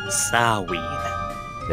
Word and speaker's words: Samhain: It's Samhain. – Beg your pardon? – It's pronounced Samhain. Samhain, not Samhain.